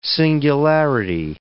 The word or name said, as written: singularity